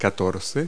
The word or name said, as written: fourteen